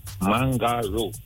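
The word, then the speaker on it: Mangaroo: M-A-L? M-A-L, right. And what's your last name? – Mangaro. – Spell that for me. – M-A-N. – M-A-N. – G-A-R. – G-A-R. – Double O.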